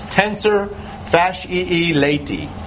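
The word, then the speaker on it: tensor fasciae latae: Anyway, there are two different vowels at the end of this middle word. It's tensor fasciae lati.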